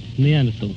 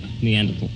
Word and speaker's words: Neanderthal: How do you pronounce it? – Neanderthal. – Oh, is it easy as that? Neanderthal.